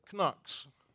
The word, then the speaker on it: Knox: Don't pronounce his first letter, but Knox. Okay, Knox.